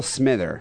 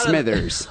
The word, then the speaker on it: Smither: Neal Smither, not Smithers.